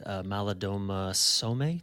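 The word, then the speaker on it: Malidoma Somé: And I'm struck by something that one of your friends said, Maladoma Somme. Did I pronounce his name right? – Yes. Mm-hmm.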